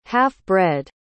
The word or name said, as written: half-bred